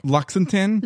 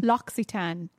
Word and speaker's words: L'Occitane: L'Occitane? – L'Occitane.